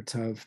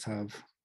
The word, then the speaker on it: Tav: So Aleph to Tav, or Tav, depending on how you want to pronounce it. Different Hebrew pronunciations pronounce it differently. I will go with Tav.